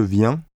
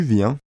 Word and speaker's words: viens: Note that the vowel in the three singular forms of venir, je viens, tu viens, il vient, is the same nasal vowel un as in bien, and that the N is not pronounced.